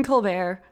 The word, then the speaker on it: Colbert: And it turns out The Late Show, hosted by Stephen Colbert, Stephen Colbert.